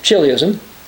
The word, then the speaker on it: chiliasm: As a sign of millenarianism, that's believing in the 1,000-year millennial reign of Jesus Christ, also called kiliasm, we find the Greek letter chi. Kiliasm is C-H-I, so we could pronounce it chiliasm.